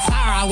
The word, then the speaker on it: Sarah: Hello, my name is Sarah. – Yeah. – I'm Sarah with an H.